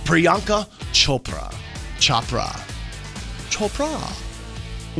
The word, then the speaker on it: Priyanka: Is it Priyanka? – That sounds good. – Is that how you would say it? – Mm-hmm. – Hello, Priyanka. – If you ever meet her, make sure you pronounce it like that.